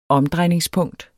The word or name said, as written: omdrejningspunkter